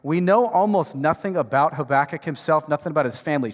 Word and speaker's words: Habakkuk: I just said Habakkuk. Some of you guys, no, it's Habakkuk. Well, that's fine. We figured it out. It's Habakkuk. That's what I'm going to say.